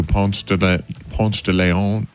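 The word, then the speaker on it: Ponce de León: We may have found the new Ponce de Leon. – What does that mean? – You know who Ponce de Leon, the guy who supposedly, am I saying that wrong? – No, you're saying it right.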